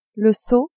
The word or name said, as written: seau